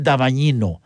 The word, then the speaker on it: Davagnino: Oye, quiero mandar un saludo respetuoso, cariñoso, con admiración, a don Miguel Damañino. Se escribe D-A-V-A-G-N-I-N-O.